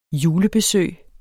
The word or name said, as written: julebesøg